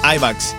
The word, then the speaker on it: IMAX: IMAX. – Sí, IMAX.